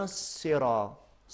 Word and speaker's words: الصراط: بعد میں اہدنا الصراط، صاد ہے، سین کو اگر موٹا تلفظ کریں گے صاد بن جائے گا.